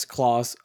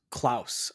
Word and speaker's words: Klaus: Yes, Claws. Or it's actually pronounced Klaus. I think it's Klaus.